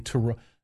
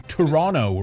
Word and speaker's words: Toronto: Toronto. What is it the Canadians say again? Toronto, right? Not Toronto. Toronto. To say it right.